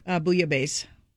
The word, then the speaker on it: bouillabaisse: Bouillabaisse.